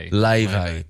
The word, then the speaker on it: Laufey: Leve. – But she's spelled L-A-U-F-E-Y.